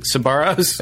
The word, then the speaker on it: Sbarro's: Most embarrassing way to die: while getting spray tanned or choking at Sparrows? – Sparrows? – Is it Sbarro's? – I think it's Sbarro's. Is it? Is there a... – I pronounce it Sbarro's. – You pronounce it Sbarro's? – Yeah.